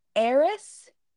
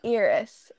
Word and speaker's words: Eris: It's supposed to be Eris with an A instead of Eris, which is how I always pronounced it because it's an E.